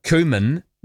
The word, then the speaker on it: cumin: Roman, cumin is pronounced cumin, just like cucumber is pronounced cucumber, not cucumber.